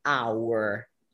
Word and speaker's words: hour: How do you pronounce this word here? Like hour. – Yeah, that would be a silent H sound.